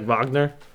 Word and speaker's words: Wagner: Isn't it like Wagner? – Mo Wagner.